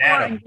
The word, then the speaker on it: natto: Oh, natto, natto, yes. – How do you spell it? – So N-A-T-T-O. – Okay. – Natto. – Okay. – Japanese would say natto. To properly pronounce it.